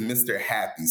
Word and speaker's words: MrJappi: It's spelled with a Spanish J. So it's MRJAPPI. So they're like, I don't understand it. If you see the name Jose spelled out, you don't say Jose, you say Jose.